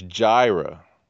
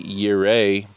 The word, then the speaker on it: Yireh: And this is the word that's commonly pronounced as Jireh. And it's just kind of like, okay, first of all, there are no J's in Hebrew. They say, you know, he's the Lord, my provider. He's, improperly pronounced divine name, Jireh. You know, and it's just kind of like, wow, okay. It's actually Yireh, but that's cool.